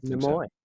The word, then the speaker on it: Nimoy: In 1985, I was directed by Leonard Nimoy, which I believe is the correct pronunciation. Nimoy. – Like Demi.